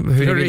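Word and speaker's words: Fleury: Eller Flurry, hur Flurry, är det uttalet? Ja. Eller Flurry, ja.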